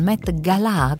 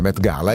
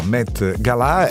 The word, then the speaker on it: Met Gala: Gli occhi di tutti saranno puntati su New York, sul Met Gala, con l'accento sulla A. – Così dicono, pare che si dica così. Noi abbiamo sempre detto l'altra versione, Met Gala, invece pare che si dica Met Gala.